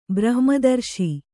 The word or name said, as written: ಬ್ರಹ್ಮದರ್ಶಿ